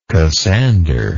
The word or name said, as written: cassander